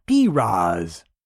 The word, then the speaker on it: Perez: From now on, Perez should be pronounced Piraz, which I think I could get down with as an announcer.